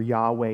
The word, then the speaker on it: Yahweh: So in other words, YHWH, Yod Heh Vav Heh, or Yahweh is how it's sometimes pronounced.